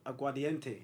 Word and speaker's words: Aguardiente: Went out, like, went out and She's Colombian, so she bust me on some drink called Aguardiente. Yeah? – Whoa, that sounds mad. – But you pronounce that really well, by the way.